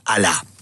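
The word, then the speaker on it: alá (ἀλλά): Él utiliza de las cuatro conjunciones adversativas que hay en el griego, él utiliza la más fuerte, que es alá. Se escribe A-L-A.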